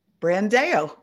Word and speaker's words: Brandao: Nice and easy. Brandao. – Brandao. All right. Just like it's spelled.